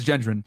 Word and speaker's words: Gendron: Peyton Gendron visited Tops supermarket 2 months. – Okay, I pronounced it correctly. It is Gendron.